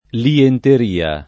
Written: lienteria

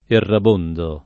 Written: errabondo

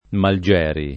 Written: Malgieri

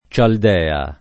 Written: Cialdea

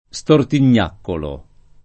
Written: stortignaccolo

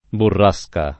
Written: burrasca

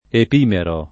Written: epimero

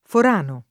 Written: Forano

